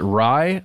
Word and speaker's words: Rai: Well, that's at least according to what I read out of, is it Rai? I think that's how you say it, R-A-I.